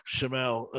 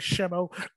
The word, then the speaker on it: Schemmel: And I think Schemmel, as much, I don't know why I'm pronouncing it differently, Schemmel,